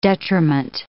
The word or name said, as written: detriment